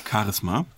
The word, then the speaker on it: Charisma: ich habe Charisma gesagt, wieso habe ich das ausgesprochen, als wäre es ein Pferd. Charisma.